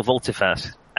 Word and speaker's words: volte-face: subtle volte-face. Volte-face, is that how you say it? – Yes, you turn around and head back the other way.